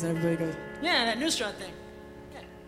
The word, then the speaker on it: Nusrat: Because I have a friend named Penny, and I played her the thing, and she said Nasrat. – Yeah. – It's Nusrat, and he's my Elvis.